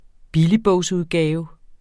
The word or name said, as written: billigbogsudgave